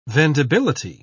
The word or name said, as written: vendibility